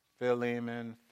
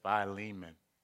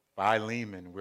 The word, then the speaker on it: Philemon: You might hear some people say Philemon. When I looked at it, it says Philemon. Okay? So Philemon, we're going to go with.